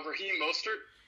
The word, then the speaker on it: Raheem Mostert: Raheem Mostert.